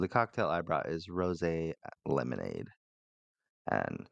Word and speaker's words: rosé: So to go with this cocktail, to go with this story, I have a cocktail and I understand that rosé is pronounced rosé, but it's spelled as rose.